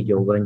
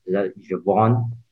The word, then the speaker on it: Jevon: you can call me Jovan, Jovan, but I usually pronounce it with a bit of French lingo, Jevon.